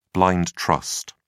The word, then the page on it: blind trust: British English